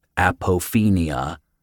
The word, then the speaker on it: apophenia: Apophenia, Paul repeated, emphasizing each syllable.